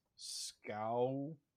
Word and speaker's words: Skowronek: Bennett Skowronek. Pronunciation. Doing research on Skowronek. Skowronek. Skowronek. That's how you say it. Skowronek. According to Wikipedia.